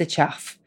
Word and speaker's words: chough: Um, it is spelled C H O U G H. That is the chuff.